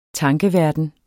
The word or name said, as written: tankeverden